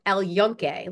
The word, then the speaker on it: El Yunque: So one of the highlights that we did was there's a very famous rainforest there in Puerto Rico called El Yunque, like Junque is how it's spelled. And it was, El Yunque with a J.